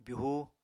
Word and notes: byuhu: apatani

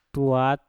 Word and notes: tuat: mpur

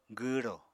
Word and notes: gwwrò: apatani

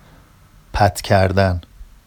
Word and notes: pat cardan: Vafsi

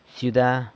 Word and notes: syuda: city

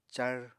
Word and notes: car: apatani

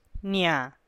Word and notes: nia: Teotitlán del Valle Zapotec

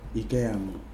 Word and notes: anyeitumese: Kwamera

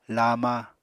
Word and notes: lama: apatani

